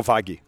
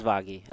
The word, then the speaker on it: Vaghi: Is it, isn't it, is, it, is there an, actually a G sound there? Is it Vagi or is it Vahi? – No, Vagi. Vagi? Vagi. It yeah. is Vagi. Okay.